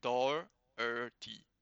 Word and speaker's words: Doherty: There's no Doc. It's not Doherty. It's Doherty. It's like Doherty.